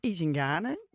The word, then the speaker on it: Izingane: Izingane is Izingane, zoals ik het zeg. En het is het Zulu woord voor kinderen.